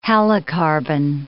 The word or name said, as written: halocarbon